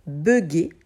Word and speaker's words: bugger: Bugger, c'est un anglicisme puisque ça vient du mot bug, to bug en anglais, euh, en français, on va le prononcer avec le son E, bugger.